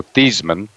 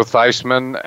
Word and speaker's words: Theismann: This is before he went, so his name was still pronounced Joe Theismann, uh, as in it did not did not rhyme with Heisman.